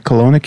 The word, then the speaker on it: Kelowna: Kelowna, Canada? – That is correct, and you even said it correctly.